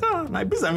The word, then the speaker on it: Ibiza: I'm just surprised, like, Asake pronounced the Ibiza Ibita, and I'm like, Ibiza, me, I recall her.